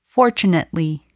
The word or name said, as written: fortunately